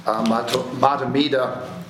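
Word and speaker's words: Mahtomedi: This sermon was originally given by Reverend Victoria Safford, who is the minister at White Bear Unitarian Universalist Church in Matamida, Minnesota. And I pronounced that correctly because Renee Keating, who's here with us today, her sister goes to that church and she knew how to pronounce the town.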